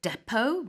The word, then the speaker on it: depot: I think you can also pronounce it depot, but you never say pot. – Yeah. – At the end.